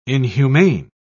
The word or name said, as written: inhumane